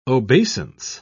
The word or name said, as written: obeisance